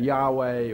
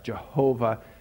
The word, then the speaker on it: Yahweh: And Yahweh, or as sometimes is mistakenly pronounced, Jehovah, means I am who I am, or I will be who I will be.